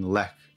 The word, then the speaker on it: Lech: is helping to organize an event in Lech. I'm not sure how to say it, L-E-C-H. – I think you got it.